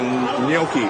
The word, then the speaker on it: gnocchi: I want the gnocchi. How do you say it, gnocchi? – Gnocchi.